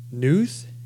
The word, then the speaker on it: Knuth: And Donald Knuth, is that how you would say that? K-N-U-T-H? The K is silent? – The K is silent, yeah.